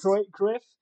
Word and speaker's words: Cruyff: And actually, Johan Cruyff, or I'm probably mispronouncing that name. – No, that sounds right. That's how I've always heard it pronounced. – Cruyff? – Yeah, that's the one.